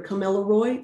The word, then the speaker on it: Gomeroi: So we live on Gomeroi country, or Camillaroi, it's often pronounced different ways.